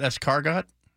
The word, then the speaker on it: escargot: So you don't pronounce the T, it's not escargot?